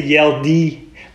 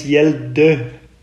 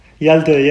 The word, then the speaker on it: Hjalte: Actually, you say, Americans, they always say Hjalte, but it's Hjalte. But you don't have that sound. Hjalte, yeah.